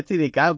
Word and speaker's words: Steadicam: Sí, Aparte de las TD Camp, eh, se ve mucho el acompañamiento a los gestos. de, ¿Cómo se dice, tío? No, no, no, se dice Steadicam, pero que la Steadicam de ellos.